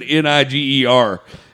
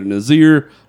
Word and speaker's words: Niger: And he come back and he's like, they pronounce it either Nazir or Niger, but still with a hard R, which is weird.